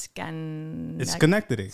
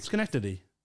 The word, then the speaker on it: Schenectady: It's Schenectady. – Schenectady.